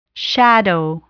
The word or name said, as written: shadow